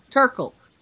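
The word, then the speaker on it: Terkel: I think it was one of your American authors named Studs Turtle. Studs Turtle. I think I've got his name right. No, Turkle. With a C.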